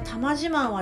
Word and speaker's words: 多満自慢: こちら玉自慢はですね、